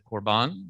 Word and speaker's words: Corban: For instance, in today's gospel reading, the lectionary cut out this whole section on Corbin, or as Jason probably more properly pronounced it, Corban, I think.